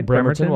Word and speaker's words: Bremerton: Bremerton, Washington.